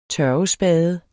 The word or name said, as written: tørvespade